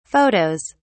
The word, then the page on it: photos: British English